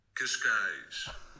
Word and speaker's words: Cascais: Cascais.